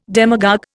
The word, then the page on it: demagogue: Indian